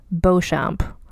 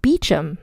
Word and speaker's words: Beauchamp: I pronounced his title Lord Beauchamp because that's how it's pronounced if you're a French person. It's B E A U C H A M P. Beauchamp, I would think. But in fact, because British people gotta be British, it's pronounced Beecham.